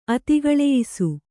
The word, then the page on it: ಅತಿಗಳೆಯಿಸು: kannada